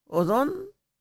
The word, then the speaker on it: Odón: Odón, O-D-O-N, con acento en la O.